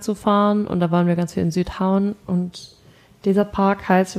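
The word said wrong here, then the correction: Sydhavn: Ich muss das Wort geschrieben sehen, aber Südhauen wird auf jeden Fall Sühauen. – Okay. Sühauen, ja.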